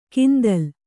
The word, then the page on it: ಕಿಂದಲ್: kindal